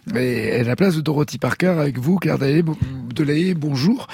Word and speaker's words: Dorothy Parker: Alors, en 1956, Dorothy Parker donne une interview au Paris Review.